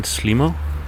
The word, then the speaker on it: SLIM0: Walking down a quiet street now, and you just heard the song Teddy Savero by Danish band Slimo. They spell it S-L-I-M and then a zero, but they usually pronounce it Slimo.